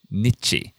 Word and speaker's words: niche: Niche. – Niche.、 Mm. – Well, now, that's very different to how I say it, which is niche. – Niche. – But it's actually very close to how it's pronounced in US English.